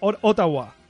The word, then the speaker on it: Ottawa: Ottawa. – Ottawa. – Ottawa. Efectivamente. Ottawa, Esto, pronunciación canadiense, igual que Toronto. Los americanos dicen Toronto, los eh, de Toronto nativos es Toronto.